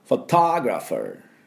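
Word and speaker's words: photographer: ですからフォトグラファー、フォトグラファーがイギリスの音になりますし、アメリカ人だったらフォトグラファー、フォトグラファーっていうふうに最後に R を持ってくると思います。まあどちらでも結構です。好きな方を選んでいただいて、まあ、この発音のね、大事なとこだけはしっかりね、出していただきたいと思います。特に F 大事ね。フォットのフォーも最後のファーもサイレント、なんエアークサブね。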